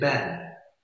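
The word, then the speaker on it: ben (בן): The word in Hebrew for son, he sends his son into the vineyard and they'll kill him, is ben, very simple word in Hebrew. Stone is even, and it rhymes with ben.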